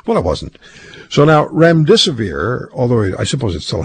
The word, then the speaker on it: remdesivir: If I say remdesivir to you, I don't even know if I'm saying it correctly. – Yeah, you are. – Am I? – Yeah. – Okay, so if I say remdesivir to you, and I can't remember how to say the other one, hydroxychloroquine? – Yeah, hydroxychloroquine.